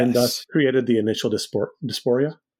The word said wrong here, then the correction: diaspora: Diaspora. – Diaspora. I can never say that correctly.